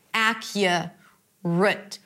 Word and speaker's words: accurate: Accurate. Let's break it down. As in cat, ah, that's the primary stress. A, q, q, q. It's a k sound, a y, and a schwa. Accurate.